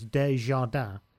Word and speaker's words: Des Jardins: Anyway, this is from, it's spelt Des Jardins, but that's obviously French, it's Des Jardins.